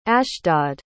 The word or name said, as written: ashdod